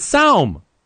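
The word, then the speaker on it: sawm: It's not swan. It's saum. I mean, I'm sorry, but at least I pronounce it correctly.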